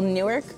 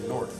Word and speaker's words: Newark: Well, Newark, but no one says that. – Yeah, you say Nork. – Yeah, but it's. I also say the city as Nork.